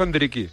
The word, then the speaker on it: Endrick: ¿Endrick o Hendriki. Hendriki. – Endriki, No, acertó, nadie. Sí. Ya te, lo dije. Endriki.